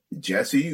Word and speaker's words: Jeshy: Django Django, Earth Eater, Jesse, who, it's spelled J-E-S-H-Y, but that dude is amazing.